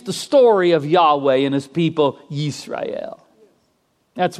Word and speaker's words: Yahweh: And since there's no vowels, it's not certain how it's pronounced, but the tetragrammaton is generally believed to be pronounced as Yahweh.